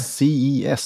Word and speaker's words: CES: Eh, CIS skulle jag säga att det uttalas.